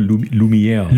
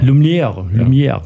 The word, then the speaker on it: Lumière: Brüder Lumière, wenn ich das richtig französisch ausgesprochen habe. – Ich glaube Lumière. – Lumière, genau.